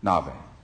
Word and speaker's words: Nabi: There's a Hebrew word, it's, it's spelled N A B I, Nabe.